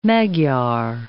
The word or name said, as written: Magyar